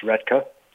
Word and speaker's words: Roethke: So, yeah, so just a minor pronunciation correction. His name is pronounced Roethke.